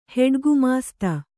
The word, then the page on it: ಹೆಡ್‍ಗುಮಾಸ್ತೆ: kannada